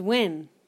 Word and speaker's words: Nguyen: This last name is not pronounced the way you pronounced it, but... – Which one? – Nguyen. – James Nguyen. – It's Nguyen. – You can say it either way.